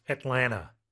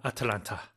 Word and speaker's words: Atlanta: And pronunciation, usually it's just pronounced Atlanta, right? Instead of Atalanta. – 그렇죠. 이건 한국식 발음이니까. 네. 애틀란타, 뭐 미국식으로 말 아틀라나. 그래서 세개 음절이에요. 우리는 애틀랜타 사음절로 한국식으로 하지만 영어로는 삼음절. 자, 근데 여기서 Ted Turner saw himself in Atlanta 하셨어요.